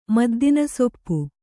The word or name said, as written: ಮದ್ದಿನಸೊಪ್ಪು